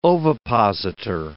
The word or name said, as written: ovipositor